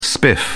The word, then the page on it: spiff: British English